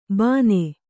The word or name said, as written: ಭಾನೆ